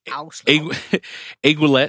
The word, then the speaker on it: aiguillette: Aiguillette.